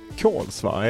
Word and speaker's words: Kolsva: Du lämnade Kolsva, är det så det uttalas? – Ja.